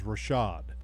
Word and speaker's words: Rashad: Well, first and foremost, JR, I want to just correct your pronunciation. It is Rashad, okay? – And Lagerman.